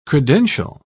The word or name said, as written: credential